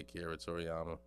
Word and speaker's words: Akira Toriyama: I'm going to just say Akira Toriyama.